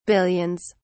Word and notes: billions: British English

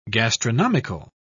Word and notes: gastronomical: British English

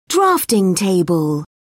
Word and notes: drafting table: British English

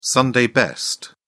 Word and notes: sunday best: British English

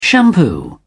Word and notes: shampoo: British English

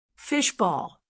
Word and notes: fish ball: American English

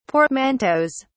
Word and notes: portmanteaus: British English

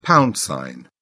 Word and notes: pound sign: British English

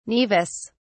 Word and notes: naevus: British English

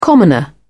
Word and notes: commoner: British English

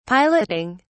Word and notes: piloting: British English

